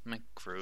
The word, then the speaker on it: MacGruber: MacGruber.